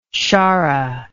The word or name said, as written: sharra